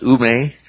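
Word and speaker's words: ume (οὐ μή): Looks like ao me, only it's pronounced ume, okay?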